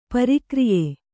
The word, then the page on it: ಪ್ರಕ್ರಯ: prakraya